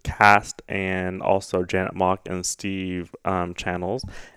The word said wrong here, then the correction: Canals: and Stephen I, Canals.